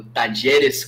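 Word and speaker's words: Talleres: Talheres, Tacheres, Tajeres, Tajeres, como é, como é que fala? – Eu acho que no, no castelhano, esses dois L's fica J, né? É o Tajeres Córdoba.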